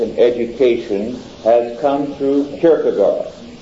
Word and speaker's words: Kierkegaard: The great figure in existentialism is also the great figure in molding neo-orthodoxy, Kierkegaard. K-I-E-R-G-E. K-I-E-R-G-E. No, K-I-E-R-K-E, excuse me, G-A-A-R-D. Kierkegaard, it sounds, but Kierkegaard is pronounced.